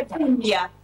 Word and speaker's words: Puglia: Se lo van a encontrar que Puglia se escribe Puglia, GL, sí, pero se dice Puglia. Pero cuando vean GL en italiano se lee como doble L.